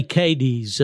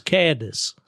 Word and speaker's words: Cadiz: And, yeah, they say Cadiz over there, but if you go to Ohio, it's Cadiz. So, you know, there's one up there, too. Yeah, they always call it Cadiz, I think.